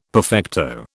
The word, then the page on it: perfecto: British English